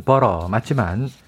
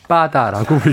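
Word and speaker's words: butter: 원래 제대로 된 발음은 버터 맞지만 우리에겐 늘 빠다라고 불려왔죠.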